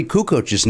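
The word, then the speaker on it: Kukoč: We mispronounced Tony Kukoc's name for 10 seasons. – Why? What is it? I think you just said it right. Kukoc.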